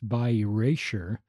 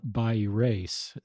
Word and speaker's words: bi erasure: It's bi erasure, not bi erase.